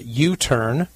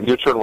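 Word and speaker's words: YouTern: So, well, I know you, you're also the CEO and founder of another company, if I'm saying this right, U-Turn, which is T-E-R-N, not U-Turn like making a U-Turn, but U, Y-O-U-T-E-R-N.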